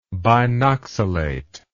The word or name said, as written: binoxalate